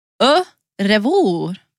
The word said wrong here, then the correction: Au revoir: Agneta. – Au revoir.